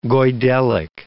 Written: Goidelic

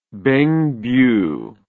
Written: Bengbu

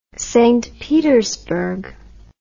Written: St Petersburg

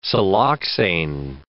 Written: siloxane

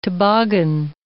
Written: toboggan

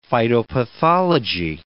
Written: phytopathology

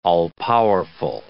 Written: all-powerful

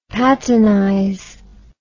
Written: patinize

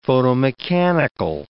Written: photomechanical

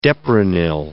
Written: deprenyl

